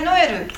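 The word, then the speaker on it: Noël: これノエルっていうのも結局ここに点々ついてるんですね。だから分けて読みますってことで、おえと読みますからノエルになるっていうことです。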